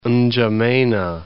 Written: N'Djamena

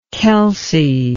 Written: Kelcey

